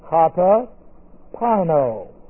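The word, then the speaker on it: Kata pino (καταπίνω): Kata pino. And we'll know something about it. This is K-A, the prepositional prefix. K-A-T-A, that's a preposition. Prefix to the word pino, P-I-N, long O.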